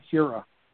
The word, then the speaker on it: Hiura: I don't think you pronounce the I, so it's Keston Hiura.